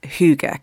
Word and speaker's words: Hygge: You've written lots of books, a lot of them looking at the very simple things in life, like making memories and Hygge. Can you tell us a little bit about Hygge? – I sure can, but first I must compliment your pronunciation of Hygge. So well done.